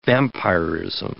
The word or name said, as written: vampirism